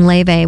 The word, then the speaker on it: Laufey: So it's pronounced just a little bit different, but we heard from Laufey. We heard the title track off her LP titled Everything I Know About Love. Well, from the vocal talent of Laufey and the shoulder shrug and dance moves of the Blasters, let's go over to the jam band Goose.